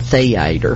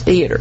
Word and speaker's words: theater: He said, well, now, first of all, let's learn to pronounce it. It's not theater. I want to be in the theater. He said, it's theater.